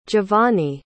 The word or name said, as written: giovanni